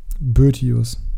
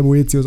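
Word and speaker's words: Boëtius: Boetius? – Boetius. Ich weiß, dass der Boetius ausgesprochen wird, ist völlig richtig.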